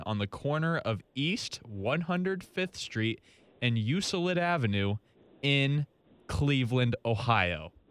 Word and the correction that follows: Euclid: It's pronounced Euclid. – My bad. Euclid Avenue in Cleveland, Ohio.